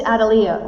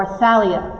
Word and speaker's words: Athaliah: Listen, who is Adaliah or Athaliah, however you want to say it? Adaliah is easier for me to pronounce.